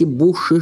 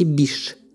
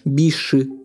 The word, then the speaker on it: Биш: Забавно, что у меня в школе всегда говорили «Перси Буши Шелли», хотя его оригинальное произношение – «Перси Биш Шелли» но во многих энциклопедических словарях он на старый манер Биши Шелли.